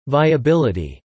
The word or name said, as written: viability